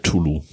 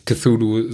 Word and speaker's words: Cthulhu: Mm, vor allen Dingen heißt er Tulu. Das C ist silent. – Cthulhu sage ich jetzt überall so, höre ich auch überall so, deswegen werde ich es weiter so sagen. – Ja, das ist die deutsche Aussprache.